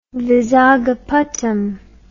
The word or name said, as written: vizagapatam